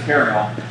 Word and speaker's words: Carmel: I know, I'm like, a mountain of Carmel? No. They pronounce it Caramel.